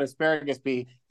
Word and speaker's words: Asparagus Pea: I'd pronounced the P. And then it wasn't until I met the man that he said, no, my name is Asparagus P. – Oh, Lordy. – Not Asparagus.